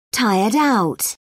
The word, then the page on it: tired-out: British English